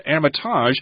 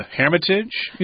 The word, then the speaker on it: Hermitage: This word is pronounced different ways. I looked this up in the dictionary, and it said Hermitage, but she said they call it the Hermitage Museum? – Hermitage, yeah.